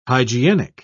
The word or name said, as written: hygienic